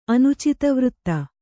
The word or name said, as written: ಅನುಚಿತವೃತ್ತ